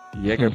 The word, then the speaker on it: Jäger: Jäger pra pronúncia alemã, mas Moving Like a Jagger.